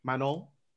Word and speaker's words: Manon: I think if they let Manon fight, like, I hope I'm saying her name right. – Manon.